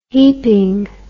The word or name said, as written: heaping